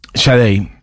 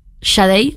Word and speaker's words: Sade: Yo tengo entendido que es Sade, pero bueno. La durante mucho tiempo le dije Sade, como el marqués de Sade, pero parece La canción que, estamos escuchando es Your Love is King.